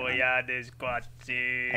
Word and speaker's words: Koyaanisqatsi: Koyaanisqatsi.